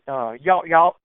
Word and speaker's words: Yawt Yawt: It's Yawt Yawt, which is YAWT.